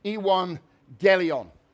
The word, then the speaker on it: evangelion: It's the evangelion. That's what the word means in the Greek. I had a Greek man on YouTube pronounce it for me. Evangelion. I was trying to pronounce it Evangelion.